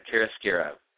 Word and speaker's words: chiaroscuro: Chorizo? What is it? Shira? Duro? – Hot or hot mess, I'll ask you. – I mean, my pronunciation of it is a hot mess, absolutely. – Yes, it's chiaroscuro.